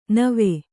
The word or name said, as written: ನೆವ